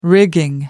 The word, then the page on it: rigging: American English